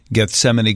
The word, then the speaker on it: Gethsemane: It's over the pronunciation of G-E-T-H-S-E-M-A-N-E. Here in Chicago, we know the Gethsemane Garden Center, Gethsemane. It's a word in the Bible and stuff. On Jeopardy, that Gethsemane would have been the right answer. One contestant did not pronounce the N at the end.